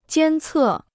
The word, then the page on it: 监测: jiān cè